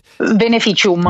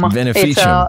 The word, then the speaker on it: Vinificium: I wanted to just quickly go over the song Vinificium, I believe is how it's pronounced. – Vinificium. – Vinificium. – It's Latin. Yes.